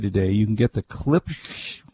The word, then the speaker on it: Klipsch: K-L-I-P. Klipsch. Klipsch. Klipsch. Klipsch. Okay, Klipsch.